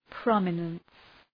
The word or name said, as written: prominence